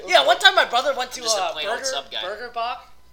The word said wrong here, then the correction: Bach: Batch. It's pronounced Batch. Excuse me. – Burger Batch. – It's Batch. It says on the menu, pronounced Batch.